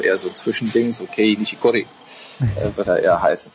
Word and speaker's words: Kei Nishikori: Nee, Kei Nishikori. – Also er, Gei Nishikori ist,